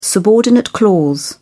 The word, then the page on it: subordinate clause: British English